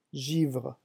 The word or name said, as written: givre